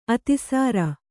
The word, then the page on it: ಅತಿಸಾರ: atisāra